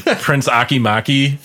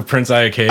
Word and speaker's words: Prince Iakea: He could probably type in best 10 WCW Jericho promos on just him on YouTube mispronouncing his opponent's name, like Prince Akimaki for Prince Iakea, and like it was gold.